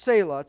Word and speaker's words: Selah: It's pronounced Selah.